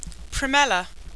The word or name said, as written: Primella